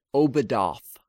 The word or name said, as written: Obedath